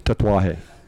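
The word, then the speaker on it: Tatuaje: But I'm putting an extra syllable in there. – Right. Yeah, it's just Tetuaje.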